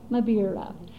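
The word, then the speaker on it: mbira: Did I say that right? – Mabira,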